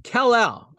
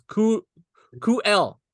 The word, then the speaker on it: Kal-El: When it builds, and once again, dude, so it's Kal-El, all right? So that's how it's pronounced.